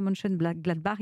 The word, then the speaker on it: Mönchengladbach: Marcus Thuram qui joue avant-centre au Borussia Mönchengladbach, il a 23 ans.